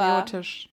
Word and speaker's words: patriotisch: ich glaube, ich meine, man muss auch sagen, wir kommen aus Deutschland, was absolut nicht patriotistisch ist. – Aber. Patriotisch. – Habe ich falsch gesagt? Ja.